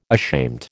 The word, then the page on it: ashamed: American English